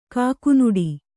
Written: ಕಾಕನಾಡು

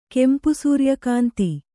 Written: ಕೆಂಪುಸೂರ್ಯಕಾಂತಿ

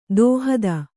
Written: ದೂಹತ್ತಿ

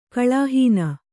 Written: ಕಳಾಹೀನ

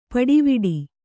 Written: ಪುಡಿವಡು

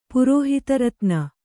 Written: ಪುರೋಹಿತರತ್ನ

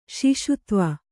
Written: ಶಿಶುತ್ವ

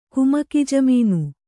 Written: ಕುಮಕಿಜಮೀನು

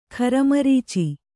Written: ಖರಮರೀಚಿ

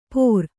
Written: ಪೋರ್

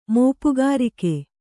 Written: ಮೋಪುಗಾರಿಕೆ

